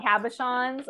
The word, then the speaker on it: cabochon: I love your stones, your little cabochons.